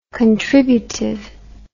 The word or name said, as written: contributiveness